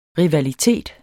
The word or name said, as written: rivalitet